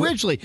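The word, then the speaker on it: Ridgeley: Andrew Ridgely.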